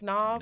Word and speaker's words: Nav: So there's a song called Lemonade with Gunna, Don Toliver, Internet Money, and how do you say it? Nav? – Nav.